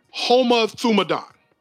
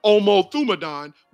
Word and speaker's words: homothumadon: called homothumadon or omothumadon, where the H is silent, from the word homos, which is H-O-M-O-S, which means one, and then thumos, which is T-H-U-M-O-S, which means passion.